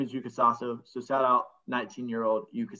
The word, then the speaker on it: Yuka Saso: I want to apologize real quick because last week I said I didn't know how to pronounce the first name of the women's open champ, U.S. Women's Open champ. She goes by Yuka. It's Yuka Saso.